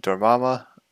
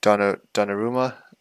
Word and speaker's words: Donnarumma: I just think Italy, like, defensively, their goalie, kind of hard to pronounce his name, Dormama, Donnarumma.